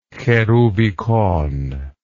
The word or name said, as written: cherubicon